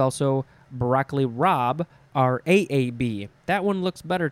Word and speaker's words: rabe: Rob looks like it's pronounced rabe, but it's not. It's rob. R-A-B-E.